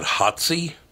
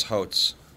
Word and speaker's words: Hotze: HOTZE. Would you pronounce that Hotzy? – Hotz? – Hotz. – Hotz? – Hotz. – Hotzy Totsy? – Hotz. HOTZE. It would be Hotz.